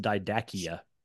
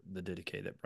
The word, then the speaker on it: Didache: If anybody comes across that word, it looks like it's pronounced Didachia. So right. It's a really odd look. It looks like a dinosaur. Is what the word looks like. Versus a Christian book, but that's the Didache that Brian's referencing.